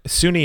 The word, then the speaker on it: SUNY: And for those people who aren't from New York, SUNY is S-U-N-Y, and it is pronounced SUNY. I have, every year I have Sunny Cortland, Sunny Fredonia, Sunny, like, no. S-U-N-Y, SUNY, State University of New York.